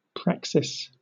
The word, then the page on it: praxis: British English